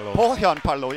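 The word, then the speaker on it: Pohjanpalo: Poyanpalo. – Ja, Jan kann Finnisch und Skandinavisch perfekt aussprechen, ich nicht.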